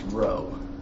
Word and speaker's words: rho: It's pronounced rho.